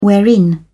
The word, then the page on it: wherein: British English